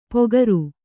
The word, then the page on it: ಪೊಗರು: Kannada